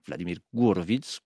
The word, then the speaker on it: Vladimir Horowitz: Eh bien cette semaine, le musicien et pianiste à l'honneur n'est autre que Vladimir Horowitz, Vladimir Gourovitz, comme le prononceraient sans doute les Russes, souvent considéré évidemment comme l'un des plus grands pianistes de l'histoire.